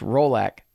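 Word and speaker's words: Rollac: I said Rolex, kind of like Rolex watch. I'm way off. It's R O L L A C and it's so it's Rolac Shutters of Texas.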